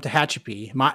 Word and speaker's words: Tehachapi: So you are from Tehachapi. I pronounced that right, right? – Yep.